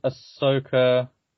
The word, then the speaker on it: Ahsoka: Yeah, you know, we've got Ahsoka coming out.